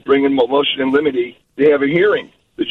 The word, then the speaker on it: limine: L-I-M-I-T-Y, like limity? – No, it's L-I-M-I-N-E. – Limine.